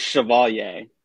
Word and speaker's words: Chevalier: It's Chevalier.